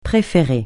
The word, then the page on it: préférer: francais